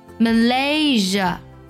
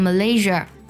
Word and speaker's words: Malaysia: 那么类似呢，还有这几个国家容易读错，特别容易给后面加儿化音，像 Australia 不要读成 Australia， Malaysia 不要读成 Malaysia， Korea 不要读成 Korea。